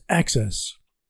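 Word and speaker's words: AHCCCS: The Medicaid program in Arizona is called the Arizona Healthcare Cost Containment System, or AHCCS, pronounced ACCESS.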